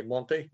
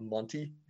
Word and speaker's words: Monte: Ishka's Polish, but she still reminds me to say Monte and not Monty.